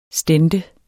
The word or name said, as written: stente